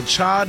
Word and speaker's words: Chai: National Cha Day. – Okay. – Did I say that right? – Yes. Like chai lattes.